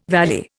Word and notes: valley: Indian

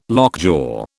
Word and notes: lockjaw: British English

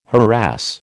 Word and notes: harass: American English

